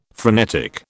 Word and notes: frenetic: British English

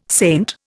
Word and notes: saint: Indian

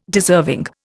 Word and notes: deserving: Indian

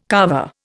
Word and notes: kava: Indian

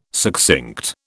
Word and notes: succinct: British English

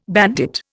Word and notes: bandit: Indian

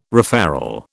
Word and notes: referral: British English

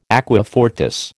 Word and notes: aqua fortis: American English

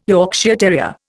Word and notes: Yorkshire terrier: Indian

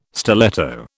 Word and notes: stiletto: British English

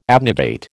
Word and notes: abnegate: American English

